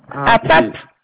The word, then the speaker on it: Appat: Appat. Appat, oui, voilà. – APAP ou APAP? APA ou APAP? – Alors, ça s'écrit Appat avec un T, mais le T ne se prononce pas.